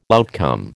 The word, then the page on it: outcome: American English